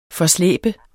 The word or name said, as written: forslæbe